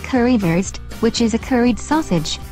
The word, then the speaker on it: Currywurst: Oh, FYI, in German, a W sounds like a V. – C, currywurst, die Currywurst, die Currywurst.